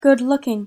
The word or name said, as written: good-looking